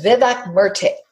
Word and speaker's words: Vivek Murthy: His name is Vivek Murthy.